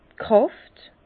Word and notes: d' Kràft: Bas-Rhin; Herrlisheim

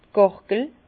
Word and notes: d' Gàrgel: Bas-Rhin; Herrlisheim

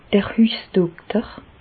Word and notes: d'r Hüssdokter: Bas Rhin; Reichshoffen

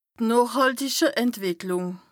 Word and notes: noochhàltiche Entwìcklùng: Bas Rhin

